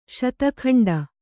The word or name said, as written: ಶತಖಂಡ